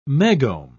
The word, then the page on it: megohm: British English